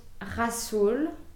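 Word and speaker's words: Rhassoul: They have some great henna powders, and this is called Rasoul. That's how we pronounce it in French.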